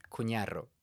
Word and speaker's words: Cunarro: You want the pronunciation in American or in Spanish? – Let's start with Spanish. – Cunarro.